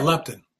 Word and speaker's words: leptin: Yeah, leptin. L-E-P-T-I-N.